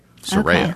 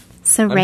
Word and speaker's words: Soraya: I actually wrote SUH-RAY-UH so that I would remember phonetically Soraya. Okay. – Soraya.